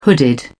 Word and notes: hooded: British English

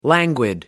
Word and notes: languid: American English

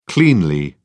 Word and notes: cleanly: British English

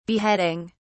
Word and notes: beheading: British English

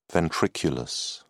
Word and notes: ventriculus: British English

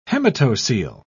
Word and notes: hematocele: British English

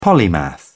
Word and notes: polymath: British English

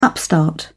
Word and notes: upstart: British English

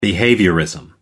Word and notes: behaviourism: American English

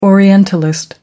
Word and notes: orientalist: American English

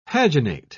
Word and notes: paginate: British English

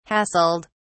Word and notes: hassled: British English

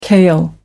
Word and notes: kale: American English

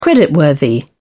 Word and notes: creditworthy: British English

